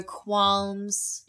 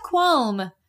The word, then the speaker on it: qualm: Just emphasis on the L there in that word. – I was going to sound like, is there normally an L in there? – There is. – Is there? – Yeah, qualm. But you don't say it like that. – I say qualm. – It is. That's how you say it. – Oh. – But it's spelt qualm.